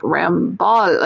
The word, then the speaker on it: Lamballe: I just said to Claudine, it sounds like Rambo or something, but it's like Lamballe, like a ball.